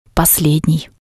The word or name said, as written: poslednii